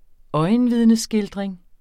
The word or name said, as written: øjenvidneskildring